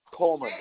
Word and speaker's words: Coleman: Coleman. C-O-L-E-M-A-N.